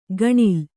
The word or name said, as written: ಘಣಲು